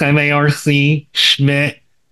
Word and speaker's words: Marc Schmidt: It's Marc Schmidt.